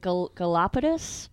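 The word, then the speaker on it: Galápagos: Am I saying this right? Galapagos? – Okay. – Yeah, you said it right.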